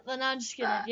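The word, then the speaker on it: Lynyrd Skynyrd: Leonard Skinner, I think. – Yeah, Leonard Skinner.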